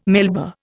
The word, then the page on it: Melba: Indian